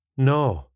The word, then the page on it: naw: スコットランド英語